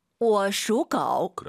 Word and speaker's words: 我属狗: 我属狗. – 그렇죠. 워 올라간 순간 틀린 발음이에요. 我属狗. 삼성이 마지막 글자만 올리지 마시고요.